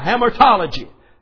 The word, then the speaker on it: hamartology: H-A-M-A-R-T. That's Hamart. H-A-M-A-R-T-O-L-O-G-Y. Hamartology.